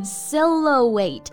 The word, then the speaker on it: silhouette: Silhouette 三个音节，S I L H O U E T T E，那中间呢还有一个字母 H 是不发音的，Silhouette。